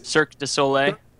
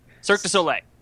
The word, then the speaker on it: Cirque du Soleil: Circus Ole? Circus. – Cirque du Soleil? – Say low. – Cirque du Soleil. – I always say Circus Ole.